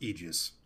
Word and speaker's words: Aegis: Well, Mike, it's pronounced Aegis.